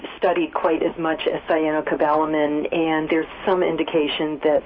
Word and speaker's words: Cyanocobalamin: Cyanocobalamin. – How do you say that? – Cyanocobalamin.